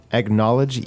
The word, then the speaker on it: acknowledge: Yeah, it sounds, first of all, the pronunciation, it looks like acknowledge, but the k is actually g, like basically it sounds like egg. Acknowledge. – Acknowledge. – Yeah, acknowledge. – Yeah, I mean, again, I think accents might be a little different. – Yes, yeah. – Because I would pronounce it more of an ah sound. – Oh, acknowledge. – Acknowledge. – Acknowledge, yeah, acknowledge. – But yeah. – The K, you're right, is minimized there.